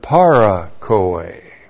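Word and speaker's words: Parakoe (παρακοή): And then with the first Adam is the word parakoe. P-A-R-A-K-O. Long E. Parakoe.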